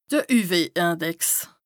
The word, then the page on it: de UV-Index: Bas-Rhin; Strasbourg